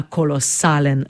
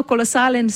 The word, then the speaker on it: kolosalen: Pri izgovorjavi poudarjamo samoglasnik A, nad njim pravopisno gledano napišemo ostrivec, zato pravilno izgovorjena beseda zveni tako. Kolosalen. Ja? Kolosalen. Pri izgovorjavi seveda pazimo tudi na to, da samoglasnik E izgovorimo kot polglasnik, to pa zato, ker pri sklanjanju izgine.